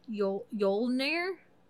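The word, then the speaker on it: Jólnir: And amongst those things, his other name was Jolnir. It's spelled with a J, J-O-L-N-I-R, but I know the J is a yeah, which is why we get Yule from the J-O-L, Yule.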